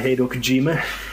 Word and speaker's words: Hideo Kojima: The Metal Gear Solid series created by Hideo Kojima.